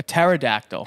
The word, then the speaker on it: pterodactyl: P-T-E-R... O-D-A-C-T-Y-L. – You're lucky that I have my notes. Pterodactyl. – No. It's pterodactyl. It's like the same way that the P is silent in psychology.